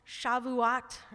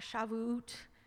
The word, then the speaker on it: Shavuot: I also think it's interesting that during this time, the holiday of first fruits, the reaping festival, Shavuot, or Shavuot, however you want to pronounce it, that grape juice or wine and oil is also symbolic of the Holy Spirit.